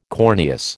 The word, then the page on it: corneous: American English